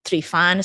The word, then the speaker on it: Trifan: So my last name is T-R-I-F-A-N, but it's pronounced in Romanian, TREEFAN.